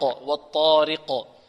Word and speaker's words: الطارق: والطارق الراء مرققة لأنها مكسورة، عندنا الراء لها أحوال، إن كانت مكسورة ماذا؟ رققت ري، وإن كانت مفتوحة أو مضمومة تفخم. رو را ري، انطقوا معي ري. – ري را رو. والطارق. طيب نرقق الراء ونحافظ على كسر كذلك، لا نقول والطارق راء راء، لا هذه إمالة، هذه إمالة ليست كسرا خالصا.